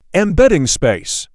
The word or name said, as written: Embedding space